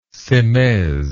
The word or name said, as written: semaise